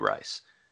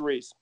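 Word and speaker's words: Rhys: I love Tom Hanks, and I stan Matthew Rice. Reese. I don't know how to pronounce the last name. – I think it's Reese.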